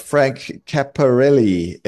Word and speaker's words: Cappelleri: And we've got Frank Cappelleri in the studio. Frank, have I said that right? – You did. Cappelleri is right. I like to say, sounds like the vein.